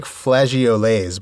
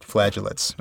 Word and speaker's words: flageolets: The word looks like flageolets, but it's actually pronounced flagellates, according to...